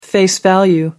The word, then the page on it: face value: American English